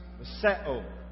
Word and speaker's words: miseo: It's a word that we pronounce miseo. Miseo. M-I-S-E-O is the transliteration, the way we write it.